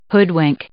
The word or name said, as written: hoodwink